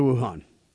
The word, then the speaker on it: Wuhan: They say it might be the United States Army who brought the epidemic to, and how do you say this, city, Wuhan? Wuhan? Is that how you say it? – That's right, Wuhan, yeah.